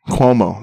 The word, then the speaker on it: Cuomo: He's trying to get fucking, what's his name? Kumo? Cuomo? – And it just came out that Cuomo had... – Cuomo, that's how you say it.